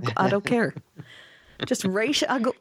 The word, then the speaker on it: Ra's al Ghul: No, it has to be Ra's, right? Because that's how it's pronounced. Please don't pronounce it any other way. It's Ra's al Ghul.